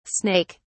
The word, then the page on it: snake(s): British English